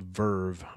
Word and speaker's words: VRV: rolled out a new subscription service called Verve, VRV is how they spell it.